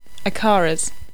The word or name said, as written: acaras